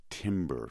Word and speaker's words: Timbre: Actually, they pronounce it Timbre because nobody could get it right. The company said they now go by Timbre. – T-I-M-B-R-E.